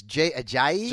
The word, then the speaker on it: Ajayi: I do worry a little bit about – now, help me pronounce this, Jay Ajayi. – There you go.